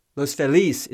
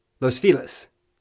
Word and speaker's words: Los Feliz: I think it's pronunciation, of the Los Angeles neighborhood Los Feliz, yeah, Los Feliz is the proper Spanish pronunciation. The Anglo way is Los Feliz, which is what most locals in LA say.